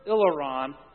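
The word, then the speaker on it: ilaron (ἱλαρόν): But the word that he uses here is the word ilaron, and it's spelled H-I-L-A-R-O-N, if you can picture that in your mind. But does it call to mind an English word? Hilarious. Yeah. So read it that way.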